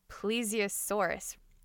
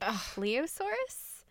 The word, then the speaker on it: plesiosaurus: I mispronounced plesiosaurus. I kept calling it pleosaurus, and my apologies for that.